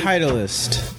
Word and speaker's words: Titleist: Say Titleist.